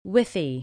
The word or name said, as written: Withy